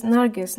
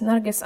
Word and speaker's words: Nargis: It is Nargis, Nargis Azad.